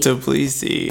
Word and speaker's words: Tbilisi: Tbilisi. Tbilisi. – Tbilisi. – That's how you say it. – Alright. – Tbilisi.